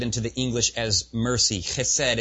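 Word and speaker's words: Chesed (חסד): Pronounce it for you like a true Jew. Chesed. Chesed. Chesed.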